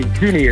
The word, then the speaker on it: Zinzi: Ja, maar GroenLinks Tweede Kamerlid Zinzi Özdil, zeg ik het goed? – Zini is het.